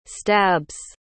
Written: stabs

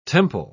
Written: temple

